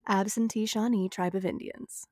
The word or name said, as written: Absentee Shawnee Tribe